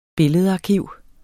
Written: billedarkiv

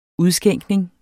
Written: udskænkning

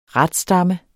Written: RATSTAMME

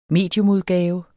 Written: mediumudgave

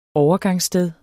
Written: OVERGANGSSTEDER

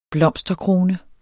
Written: blomsterkrone

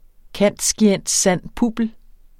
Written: cand.scient.san.publ.

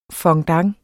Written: FONDANTER

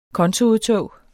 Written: kontoudtog

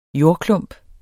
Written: jordklump

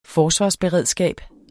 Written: Forsvarsberedskab

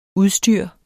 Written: udstyr

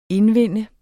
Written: indvinde

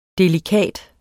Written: delikat

